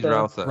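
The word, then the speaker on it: Rautha: I never had know how to pronounce his last name, Faye or whatever it is, Faye Rutha, he's Routha.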